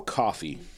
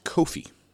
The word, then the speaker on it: Coffey: Our number 12 seed is Joe Coffey, or Kofi, as Glenn taught us. I don't know when you'll, how long it'll have been since Joe, or since Glenn taught us that Joe is pronounced Kofi, like Kofi Kingston, Joe Kofi. We call him Joe Coffey, but he is Joe Kofi.